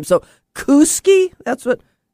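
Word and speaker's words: Kooskia: It's K-O-O-S-K-I-A. It's pronounced Kuski. – But the A is silent.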